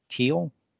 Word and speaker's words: Thiel: Thiel, is that? I think the H is silent.